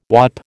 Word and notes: whaup: American English

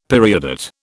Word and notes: periodate: British English

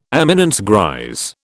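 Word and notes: éminence grise: British English

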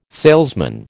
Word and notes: salesman: American English

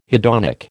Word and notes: hedonic: American English

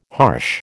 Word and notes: harsh: American English

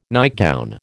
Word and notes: nightgown: American English

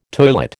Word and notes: toilet: American English

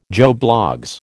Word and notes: Joe Bloggs: American English

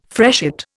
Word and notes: freshet: Indian

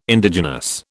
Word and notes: Indigenous: British English

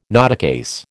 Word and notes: notecase: American English

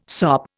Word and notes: sop: Indian

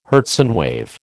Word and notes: Hertzian wave: American English

